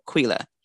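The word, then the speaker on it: Killa: So it's K-I-L-L-A. And then it's Q-U-I-L-L-A. But whether it's spelled with a K or a Q, it's pronounced as if it's the Q. So Quila. She's also known as Mother Moon.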